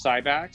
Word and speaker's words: Cybac: It's Cybac, so it's like an AK, not an ORG, and then we're good.